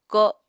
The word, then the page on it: ko7: Apatani